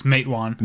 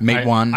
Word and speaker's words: Matewan: He did Matawan. I don't know if that's how you say it. – It's Matewan. – Matewan.